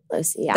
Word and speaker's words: Losey: Losey, yeah. – That's how you pronounce the last name? Do people always say Lousy? – Yeah, my last name is one letter away from loser.